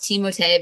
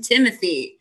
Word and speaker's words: Timothée: And, yes, I know his name is technically pronounced Timotei because of the accent, but I'm not going to do it because I'm not pretentious like that. So I'm calling him Timothy.